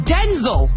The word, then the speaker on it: Denzel: Oddly enough, we're all pronouncing Denzel Washington's name wrong. – Oh, no, you didn't. – He says it's pronounced Denzel, but even he calls himself Denzel.